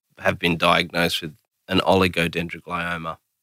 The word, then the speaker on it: oligodendroglioma: I'll give you an oligodendroglioma.